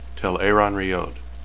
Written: telaranrhiod

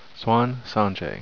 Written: siuan

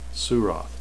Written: suroth

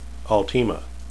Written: alteima

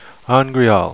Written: angreal

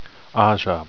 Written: ajah

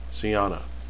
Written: seana

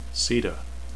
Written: seta

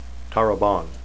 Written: tarabon

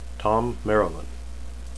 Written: thom

